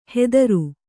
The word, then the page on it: ಹೆದರು: hedaru